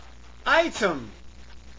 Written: item

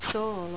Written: soolo

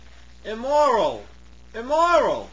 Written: immoral

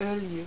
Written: oljy

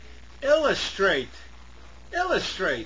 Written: illustrate

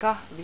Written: kahvi

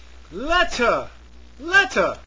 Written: letter

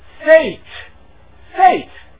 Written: fate

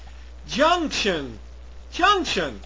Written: junction

